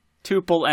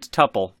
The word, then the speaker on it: tuple: There are two main pronunciations of tuple, tuple and tuple. Neither is incorrect.